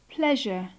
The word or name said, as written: pleasure